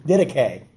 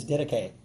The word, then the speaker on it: Didache: I heard you playing it on your thing, didache. – Yes. – The correct pronunciation is didache, you know, but that's okay, you know.